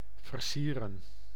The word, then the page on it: decoration: American English; /ˌdɛkəˈreɪʃən/